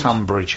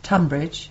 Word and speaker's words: Tonbridge: Do you say Tonbridge or Tunbridge? – Tunbridge. – Why do they spell it T-O-N then? – Oh, for goodness sake. – And why is Tunbridge Wells spelt T-U-N? – Is it different places? – Yeah, but it's all strange, isn't it? – No, different. – Kentish, because you pronounce them both the same, Tunbridge, but one is spelt Tonbridge. – Yeah.